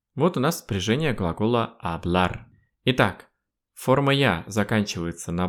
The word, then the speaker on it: hablar: Пишется глагол «hablar» через букву b в середине, «hablar», но в испанском мире чаще всего глагол «hablar» произносится как бы через букву мягкую, что-то среднее между «б» и «в».